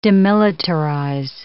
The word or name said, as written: demilitarize